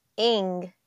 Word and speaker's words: Ng: Ng. Ng. It's it's spelt N G but it's pronounced like ing.